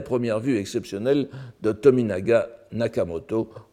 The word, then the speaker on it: Nakamoto: Et euh, pour le cas de Nak alors évidemment maintenant tout le monde veut prononcer Tominaga Nakamoto parce que il signe lui-même, nous allons voir dans la préface euh, de tout à l'heure, son nom Nakamoto, mais parce qu'il il se désigne lui-même.